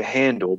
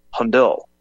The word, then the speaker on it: Handel: And I know some people say Handel, but I just looked up the pronunciation, how he would have said it in German, and it's Handel. Handel. So there is an emphasis on the EL.